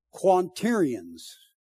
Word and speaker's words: quaternions: I've had people ridicule me on this next word because I've never pronounced it correctly. Hear me? Almost 40 years of preaching, I'm going to pronounce it correctly today. Now watch me not be able to. Quaternions. I always say something else.